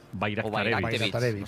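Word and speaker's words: Bajraktarević: Bayraktarevich. – Bayraktarevic. O sea, la J la conviertes en ahí, Bayraktarevic.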